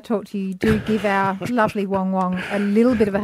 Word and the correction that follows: Wang Wang: Say it right, it's Wang Wang.